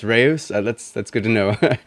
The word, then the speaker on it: Reus: Reyes is the easy pronunciation, actually. So in Dutch, we call it Reus. Reus is actually the Dutch word for giant, as many people have pointed out everywhere online. But that's the hard pronunciation, so we just call it Reyes whenever we talk in English.